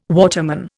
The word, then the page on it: waterman: Indian